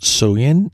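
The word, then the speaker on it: Soeun: I will do my best, but I think it's pronounced Soyun. It's S-O-E-U-N, and that's kind of part of the whole news thing.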